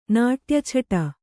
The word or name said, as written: ನಾಟ್ಯಛಟ